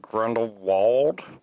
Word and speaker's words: Grindelwald: How do you pronounce, is it Grindelwald or Grindelwald? – Wald. Grindelwald. – Okay. Because I could have swore in the trailer I heard it Vald.